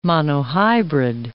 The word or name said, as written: monohybrid